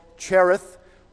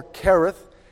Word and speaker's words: Cherith: And God led him all the way back past where he was from to the brook called Cherith, or Kereth, as it is perhaps more correctly pronounced from the Bible, would be Kereth, but many of us look at the CH and we're happy to say Cherith, and that's how we'll pronounce it for our benefit.